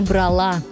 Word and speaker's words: брала: Поэтому правильно брала. К той же группе относятся и другие глаголы. Мы говорим взяла, заперла, заняла. И именно с ударением на последнем слоге.